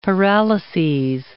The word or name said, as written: paralyses